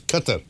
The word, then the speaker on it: Qatar: Well, my daughter, who lives in Dubai and works in that region, says the actual pronunciation correctly is Qatar. – Qatar. – So, it sounds like Qatar.